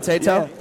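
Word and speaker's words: Tayto: Tato. I'm saying that right, Tato? – Yeah.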